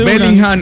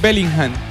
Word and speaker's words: Bellingham: Ellos dicen Bellingham, que es Bellingham. Pero acá decimos Bellingham.